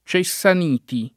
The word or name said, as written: Cessaniti